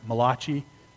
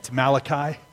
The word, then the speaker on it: Malachi: Some people pronounce it Malachi. He was not an Italian prophet. It's Malachi.